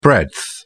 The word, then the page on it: breadth: British English